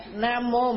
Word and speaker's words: Nam mô: Chữ mô đó, Nam mô mình đọc là Nam vô, chữ đó chữ vô mà mình đọc là Nam mô.